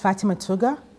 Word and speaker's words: Fatimah Tuggar: Okay, my name is Fatima Tuga.